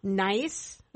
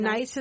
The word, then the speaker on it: gneiss: It's known as Morton Gneiss. Is that correct, Sophia? – Yep. – And Gneiss is spelled G-N-E-I-S-S.